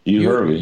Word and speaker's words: Euharlee: Euharlee, uh, that's the name of the place. Euharlee. – You, Hurley. You Hurley. Now I know I'm saying it right because I heard them say it.